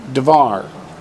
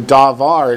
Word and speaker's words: devar: The word pair form is Devar. The only difference is, instead of a long A at the beginning, davar, it's real short, and the accent's real clear that it's on the last syllable.